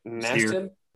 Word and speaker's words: Nassib: Uh, Carl, Nas- I'm not sure how to pronounce his last name. – Nassib. Nassib, yeah. yeah.